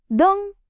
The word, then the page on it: dong: Indonesian